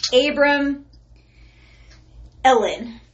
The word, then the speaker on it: Abramelin: And then there's Abramelin oil. It's like Abram Ellen.